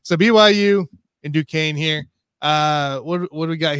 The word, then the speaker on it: Duquesne: Duquesne. – Duquesne.